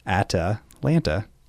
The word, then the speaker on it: Atalanta: That was Atalanta by Yamantaka Sonic Titan, and that's not me pronouncing it weird. I double-checked. That is how it's written. – What? – Atalanta.